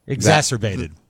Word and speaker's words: exacerbated: COVID exasperated a lot of the mental health issues. – Exacerbated. – I'm sorry. Exacerbated? – Right. Exacerbated.